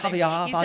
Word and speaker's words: Javier Bardem: Oh, that's right. Javier Bardem. Said it right.